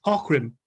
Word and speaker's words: Aughrim: It's spelled A-U-G-H-R-I-M, but it's pronounced Ockham.